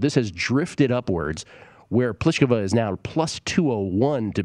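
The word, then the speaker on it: Plíšková: But as far as tomorrow, it is Pliskova, to pronounce that correctly, against Ashley Barty of Australia.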